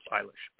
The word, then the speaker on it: Eilish: No, uh, well, it's it's pronounced Eilish, Billie Eilish. – Is it E I L I S H? – Yeah.